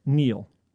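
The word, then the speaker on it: kneel: The K, Neil. Which was silent until I spelled it earlier in the clue.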